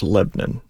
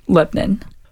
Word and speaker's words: Lebanon: Lebanon. I would argue that one's actually two syllables Lebanon. – Lebanon.